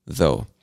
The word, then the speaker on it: though: And everything after the letter O in this word is silent. So the U, the G, the H, we do not pronounce it. It's just though.